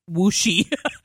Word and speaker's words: Wuxi: Wuxi. And she said, "What's a Wuxi?" I said, "Your company." She goes, "In China, an X is SH, it's Wuxi." I'm like, "Oh, sorry."